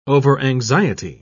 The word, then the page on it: overanxiety: British English